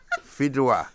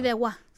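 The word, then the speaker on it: Fideuà: But how do you say it? Fiduela? Fidua. There you go.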